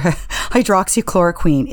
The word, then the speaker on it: hydroxychloroquine: Another thing that I think came out of this too was just President Trump had repeatedly and consistently touted the effectiveness of hydroxychloroquine, I think is how you pronounce it. – Hydroxychloroquine. – Thank you.